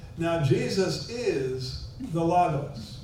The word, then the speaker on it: logos: And if you study the word in Greek, the word is logos, L-O-G-O-S. Logos, it's pronounced.